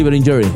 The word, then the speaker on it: Berlingeri: Sí, a ver, es ¿a Gabriela Berlingeri, así se pronuncia? – Sí, Berlingeri.